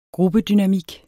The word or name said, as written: gruppedynamik